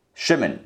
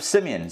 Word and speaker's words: Shimon: These are, I'm giving you the Hebrew pronunciations, these are the sons of Reuben. He has four sons Chanoch, Falu, Chetzron, and Charmi. Next son, Shimon. The sons of Simeon.